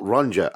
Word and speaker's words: Ronja: And it's Ronja, well, it's spelt Ronja, R-O-N-J-A, but it's actually pronounced Ronja, the Robber's Daughter.